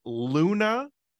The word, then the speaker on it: Lunatone: No, I'm just I don't think the E is silent. I think it's Lunatone.